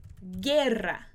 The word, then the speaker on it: guerra: E aí, quando a gente tem esse g e esse gui, o U não vai ter som. A gente não vai pronunciar nem esse U do g, nem esse U do gui. Por exemplo, eu posso falar a palavra guerra, guerra. E a palavra guitarra, guerra, guitarra. A gente não pronuncia esse U.